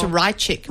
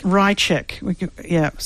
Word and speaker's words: Rychik: Mariana Ratchick. Did I say it right? – We'll hear. – Yes, I think it's Rychik. – Rychik. Something like that.